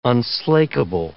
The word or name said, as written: unslakable